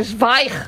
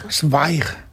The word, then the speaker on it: Zweig: Es no. Zweig. – Zweig.